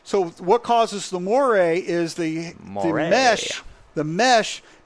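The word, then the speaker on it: moiré: Moire? It's a French term meaning it looks like shit, okay?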